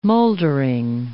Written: moldering

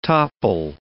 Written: topple

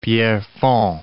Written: Pierrefonds

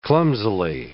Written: clumsily

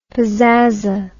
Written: pazaza

